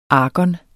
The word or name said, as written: argon